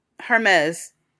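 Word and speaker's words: Hermès: Hermes. – Okay, is you pronounce the H? – Yes. It's like you pronounce it, but you don't.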